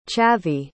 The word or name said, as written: chavi